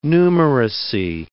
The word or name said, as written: numeracy